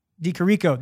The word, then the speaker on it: Di Chirico: Alessio Di Securio. I think that's wrong. – I think that's wrong. Shout too. out Shay for this one, by the way. Di Chirico. That's what it is.